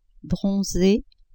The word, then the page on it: bronze: female